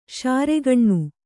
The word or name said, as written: ಶಾರೆಗಣ್ಣು